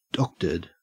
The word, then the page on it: doctored: Australian English